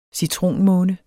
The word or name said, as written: citronmåne